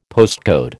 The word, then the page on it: postcode: American English